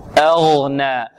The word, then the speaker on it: أغنى: نعم، الهمزة والغين مفخمة، كيف تنطقها أنت؟ كيف تقول؟ ماذا؟ أغنى أغنى، والنون مرققة، ما أغنى ما أغنى عنه ماله وما كسب.